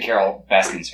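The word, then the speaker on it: Carole Baskin: He's a big Carol Baskins fan. – No. – Did I get her name right? – Yeah, you did.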